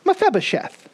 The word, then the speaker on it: Mephibosheth: For those of you who may not be familiar with the Bible, this is a good pronunciation practice, Mephibosheth.